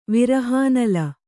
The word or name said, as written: ವಿರಹಾನಲ